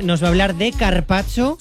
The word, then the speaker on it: carpaccio: Que te has perdido una letra. Carpaccio.